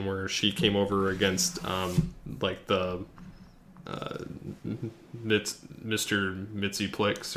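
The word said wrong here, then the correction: Mxyzptlk: or whatever. – Mitzel Pitlick. – Whatever. I can't pronounce the name. – I can say it because it was really easy to say in Smallville. Mitzel Pitlick.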